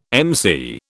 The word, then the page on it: MC: British English